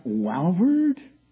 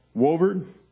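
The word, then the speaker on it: Walvoord: Wolverd. Is that how you pronounce it? Wolverd.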